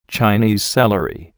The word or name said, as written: Chinese celery